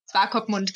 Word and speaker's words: Swakopmund: Swakopmund, genau, so spricht man da aus.